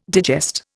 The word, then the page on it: digest: Indian